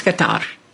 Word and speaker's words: Qatar: And I think we need to be very, very clear on that and get the pronunciation of the country correctly. Indeed, several reporters in our press conferences have expressed their offense at the fact that we keep calling them Qatar. It's not Qatar. It's Qatar.